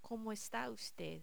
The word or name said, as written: como esta Ud